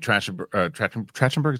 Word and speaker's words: Trachtenberg: Yeah, and Trachtenberg, is how you say it? – Yeah.